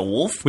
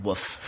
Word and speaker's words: wolf: I might like wolves and just not realize it, but it's about a boy's adventure with a wolf. – We should get you that T-shirt. – And I even say it right. I say wolf and not woof. It always makes me laugh. People say woof.